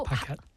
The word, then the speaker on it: Paquette: Yeah, basically it's a French name, but I'm American, so I pronounce it like an American. Paquette.